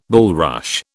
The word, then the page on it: bulrush: British English